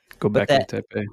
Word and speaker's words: Göbekli Tepe: The only disagreements I had were like on the ages of things, saying that something was like, you know, these stones from Göbekli Tepe. Is that right? – Göbekli Tepe. – That's it.